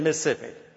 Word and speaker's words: Mississippi: When you're born in Mississippi, it's two syllables.